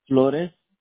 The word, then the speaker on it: Flores: Is spelled S-E-R-G-I-O? – Correct. – Okay, and what is your last name? – Flores. – F-L-O-R-E-S.